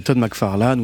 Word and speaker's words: McFarlane: Qui est Todd McFarlane? – Todd McFarlane ou Todd McFarlane en prononciation anglophone.